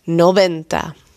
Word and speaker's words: noventa: En de V spreek je uit als B. Noventa.